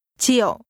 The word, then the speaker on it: triệu: Triệu. Triệu. Triệu. – This is pronounced with a low, falling, broken tone.